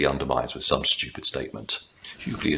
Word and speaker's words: Hughley: Complaining of paying expensive lawyers to get his daughters off charges, including one of DUI, Hughley, yes, that's how he insists it's pronounced, whines about mistreatment of blacks.